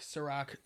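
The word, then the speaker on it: Cîroc: Yeah, like K-E-Y. – Yeah. Okay. – Okay. – And then Ciroc as in like the drink, Ciroc, C-I-R-O-C. – Yep. – I like that a lot. – But it's spelled S-U-R-R-O-K.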